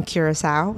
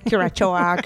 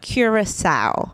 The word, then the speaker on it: Curaçao: Curaçao. – Curaçao. – The C has a cedilla under it, and that makes you pronounce the C as a S. That's why we say Curaçao.